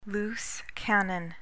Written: loose cannon

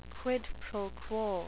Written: quid pro quo

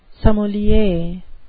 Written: sommelier

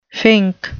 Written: fink

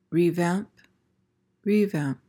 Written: revamp

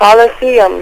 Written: coliseum

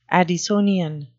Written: addisonian

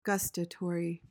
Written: gustatory

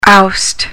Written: oust

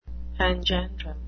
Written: panjandrum

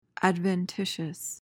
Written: adventitious

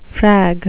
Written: frag

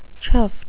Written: chuffed

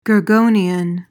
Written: gorgonian

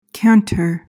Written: canter

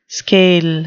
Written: skail

